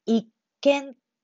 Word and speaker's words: 一見: これで一見と読みます。